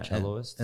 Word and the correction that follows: cellist: And they're... – How do you say that? – Cellist. – Cellist. – Yeah, I think that's what it would be.